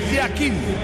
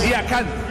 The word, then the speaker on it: Yakin: En el suizo se pronuncia así, Murat Yakin. En turco, Yakan. Cambia la última parte de su apellido.